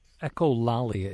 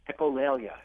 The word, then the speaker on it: echolalia: Talk a little bit about what echolalia is. Am I pronouncing that right? – Echolalia. – Echolalia, yes. Okay.